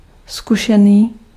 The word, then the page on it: erfaren: /ˈeːrˌfɑːr.ɛn/